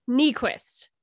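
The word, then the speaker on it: Niequist: Don't mispronounce her name. It's Nyquist. – And how do you say it? – Nyquist. – Nyquist. Okay. – Because it's the opposite of what it looks like.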